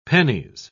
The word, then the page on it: pennies: British English